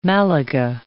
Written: Malaga

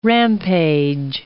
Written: rampage

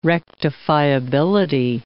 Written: rectifiability

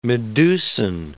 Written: medusan

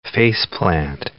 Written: face-plant

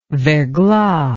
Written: verglas

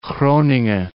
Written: Groningen

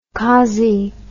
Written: cozzie

